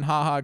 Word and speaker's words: Ha Ha: By the way, Haha says that his the pronunciation of Haha, it's supposed to be one word, but it's spelled in the press as two.